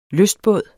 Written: lystbåd

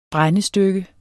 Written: brændestykke

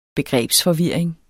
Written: begrebsforvirring

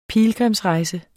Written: PILGRIMSREJSER

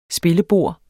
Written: spillebord

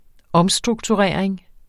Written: omstrukturering

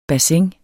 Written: bassin

